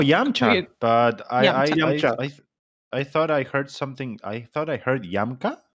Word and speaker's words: Yamcha: Wait, that's a pronunciation in Japanese, like the proper one? – Well, the proper one would be Yamcha.